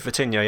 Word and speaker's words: Vitinha: But yeah, and also, is it Vitinha? Is that how you pronounce it, Dave? Vitinha, yeah? – Yeah, that's correct.